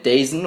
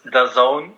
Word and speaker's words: DAZN: Ich habe gelesen, die streamen auch bei Dazen oder Dazen, wie man es ausspricht. Was genau machst du denn da? Kommentieren oder auch einfach Berichte schreiben? – Nee, also erstens heißt es DAZN, so wird es ausgesprochen.